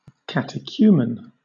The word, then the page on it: catechumen: British English